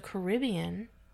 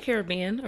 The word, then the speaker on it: Caribbean: And she actually got a free trip to the Caribbean because of it. I said that right. – Yes, you did. – I always say Caribbean. – Well, you can say Caribbean or Caribbean. It doesn't matter.